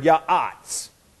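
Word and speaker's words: ya'atz (יָעַץ): That's the Hebrew word, ya'atz.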